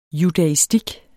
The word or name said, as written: judaistik